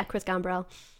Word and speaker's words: Gambrell: Is that how you say his name? – Yeah, Chris Gambrell.